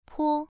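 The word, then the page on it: po: tone 1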